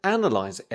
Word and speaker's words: analyse: And it's hard because the noun and the verb are very different. The sound is very different. – To analyse an analysis. – Yeah, stress changes, doesn't it? Analyse stresses on the first syllable. Analysis stresses on the second syllable.